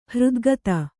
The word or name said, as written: ಹೃದ್ಗತ